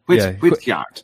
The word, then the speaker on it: Whitgeard: How do you say it? – Yeah, Whitgeard. – Whityard. – Whityard, yeah. Okay. It's, I believe it just, it's an old English word, combination of words meaning white guard.